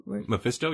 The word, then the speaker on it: Mephisto: But I, I thought um Mephisto I Mephisto, yeah.